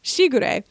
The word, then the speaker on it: Shigure: It's the emphasis on the she. Shigure.